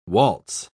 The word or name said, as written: waltz